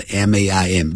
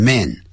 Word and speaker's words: main: main 是主要的 main 不要念成 man